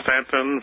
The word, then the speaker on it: Staunton: 126 Madison Place, and that's Stanton, Virginia, 24401. – And Stanton is spelled what now? S-T-A-N-T-O-N? – S-T-A-U-N-T-O-N. The U is silent. – Okay, S-T-A-U-N-T-O-N, oh, yeah.